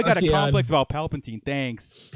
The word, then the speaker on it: Palpatine: Palpatine. – It's like Palpatine. – Yeah, Palpatine.